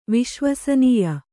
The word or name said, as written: ವಿಶ್ವಸನೀಯ